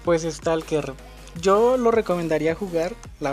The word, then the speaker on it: Stalker: ¿Lo pronuncia bien? sí, Stalker 2.